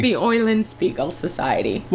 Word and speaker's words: Eulenspiegel: No, people pronounce it wrong. Okay. – Say it again. It's the Eulenspiegel Society.